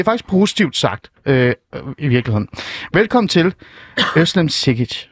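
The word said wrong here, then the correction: Özlem Cekic: Hvordan siger man egentlig det rigtigt? Åh, oh, det er virkelig, virkelig svært, men det udtales Øslem Cekic.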